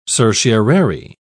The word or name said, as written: certiorari